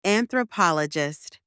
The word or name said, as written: anthropologist